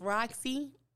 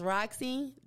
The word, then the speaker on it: Rocsi: For those who can't spell it, I'm going to say it slowly because some people say my name wrong. But it's Roxy, R-O-C-S-I. The only person that got my name is Roxy at 106 and Park. That's it. But it's Roxy, the Royalty, R-O-C-S-I-D-A. R-O-Y-A-L-T-Y, Queen Savage.